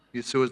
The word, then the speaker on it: Yeshua: Yeshua is the right, right way to say it.